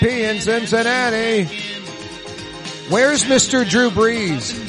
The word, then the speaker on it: Brees: I think it's pronounced Brees. Yeah, you right.